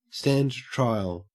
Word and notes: stand trial: /ˌstænd ˈtɹaɪəl/

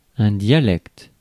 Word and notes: dialecte: France; [dja.lɛkt]; French

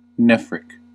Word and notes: nephric: /ˈnɛf.rɪk/

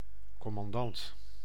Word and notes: commandant: [ko.mɑn.dɑnt]

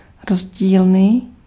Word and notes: divers: [di.vɛʁ]; France (Paris)

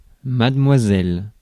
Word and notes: Mademoiselle: /mad.mwa.zɛl/; France

